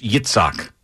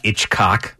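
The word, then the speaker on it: Itzhak: No, it's Yitzhak. It's not Itchcock.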